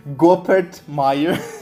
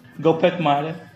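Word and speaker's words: Goeppert Mayer: Vocês sabem pronunciar o nome dela? Não. Gopert Mayer. Gopert Mayer. É Mayer, é.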